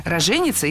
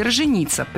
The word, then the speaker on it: роженица: Дело в том, что раньше в нашем слове допускались два ударения. Роженица и роженица. Причем роженица – вариант предпочтительный. А ударение на первом слоге – роженица – считалось ошибкой. Но сейчас все изменилось. Двойное ударение, правда, осталось. Вот только самый правильный вариант – роженица – стал неправильным. А роженица и роженица – теперь литературная норма.